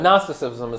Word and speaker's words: Gnosticism: All right, so we are talking about Gnosticism, which starts with a G, silent G. Although in Greek, from which it comes, it is pronounced Gnosticism, is how it might be said. But Gnosticism is how we say it.